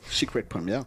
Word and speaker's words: Secret Première: Voilà, ou Secret Première, si on veut le prononcer à l'anglaise. Secret Première.